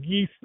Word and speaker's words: geas: My new version of that is growing closer to Christ through his cross, the GCCTHC, which I pronounce as geistic. Geis is a Celtic word, G-E-A-S, for like quest or curse or gift.